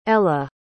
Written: ella

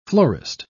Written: florist

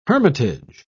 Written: hermitage

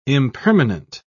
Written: impermanent

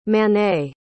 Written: manet

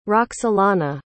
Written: roksolana

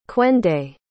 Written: kwende

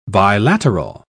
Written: bilateral

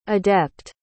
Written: adept

